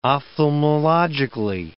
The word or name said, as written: ophthalmologically